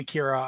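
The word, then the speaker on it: Qhira: Q-H-I-R-A. I say Kira. I'm sure there's other ways to pronounce it.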